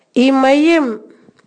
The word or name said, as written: nakamal